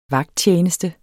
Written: VAGTTJENESTE

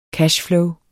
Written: CASH

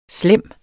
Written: slem

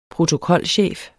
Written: protokolchef

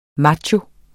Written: macho

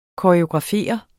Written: koreografere

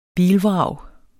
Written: bilvrag

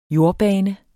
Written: jordbane

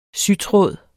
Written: sytråd

